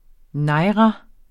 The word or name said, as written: naira